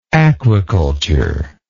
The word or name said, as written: aquiculturist